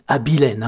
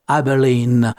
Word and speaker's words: Abilene: George Hamilton avec son I Believe, quand, comme ils disent en Amérique, hein, parce qu'en français ça se dit Abilene, hein, comme je l'ai prononcé dès le début du morceau. Mais eux, ils aiment bien dire Abilene.